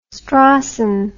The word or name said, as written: Strawson